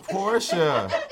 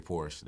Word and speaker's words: Porsche: It's Portia. Mm-hmm. A lot of the rappers do say Porsche. They fucked it up.